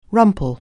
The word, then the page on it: rumple: anglais